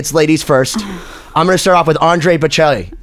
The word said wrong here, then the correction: Andrea Bocelli: Andrea Bocelli, is that how you say it? Am I fired? – Andrea Bocelli. – Yeah. Okay. Yeah.